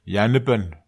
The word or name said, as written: ianepen